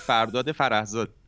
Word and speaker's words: Fardad Farahzad: And I like how you pronounced my name so Persian, you know? – You have this very North American accent and then suddenly Bandera and Zadi Fardad Farahzad. Love that.